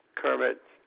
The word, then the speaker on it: Kermit: Kermit, K-E-R-M-I-T. Last name is White, W-H-I-T-E.